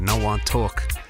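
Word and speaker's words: Nuh Wah Talk: Taking it to a tune now called No One Talk. That's not me putting on a Jamaican accent. It's spelled N-U-H, new word, W-A-H, talk, No One Talk.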